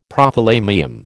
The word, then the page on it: Prothalamium: American English